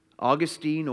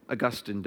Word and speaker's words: Augustine: Here's how Augustine or Augustine, depending on how you want to pronounce his name.